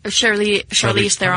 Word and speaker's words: Charlize Theron: The other character, Clarice Theron. – Charlize Theron.